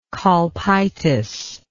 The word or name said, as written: colpitis